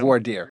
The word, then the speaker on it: voir dire: Voir dire.